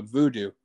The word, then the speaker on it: Vudu: All the new movies really go up there, but yeah, I've been watching Voodoo, which is VUDU. That's how it's spelled.